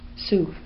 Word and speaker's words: Sioux: And there is the, how do you pronounce this word? – Sioux.